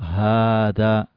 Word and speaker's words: هذا: وفي القرآن هذا، كلمة هذا أصلها في النطق هذا، لما رب هذا البيت هذا البيت، فوضعت الألف اختصارا لهذا المد، لكنها تنطق هذا.